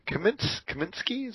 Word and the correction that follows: Comiskey: is that how he pronounces his name? Comiskey. – Comiskey, right? Okay.